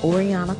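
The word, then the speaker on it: Orionna: Oriana Coleman on Facebook.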